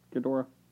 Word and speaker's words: Ghidorah: Ghidra. – I never say it right. Ghidorah.